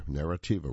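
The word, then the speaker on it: Neretva: And by the way, I mispronounced the river. It's Narativa, is I should say. I was looking at one vowel too many there, Narativa River.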